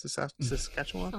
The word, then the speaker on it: Saskatchewan: Is that how you say it? Saskatchewan? – Sounds right.